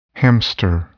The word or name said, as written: hamster